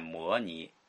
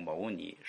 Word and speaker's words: 牟尼: 所以不要念摩尼，要念成牟尼，释迦牟尼佛。好，我们就结束吧。